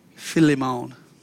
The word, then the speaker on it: Philemon: In Philemon, and I like to call it Philemon, but I think the correct pronunciation is Philemon.